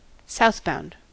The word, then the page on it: southbound: American English